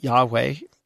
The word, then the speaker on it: Yahweh: Today's scholars pronounce it Yahweh.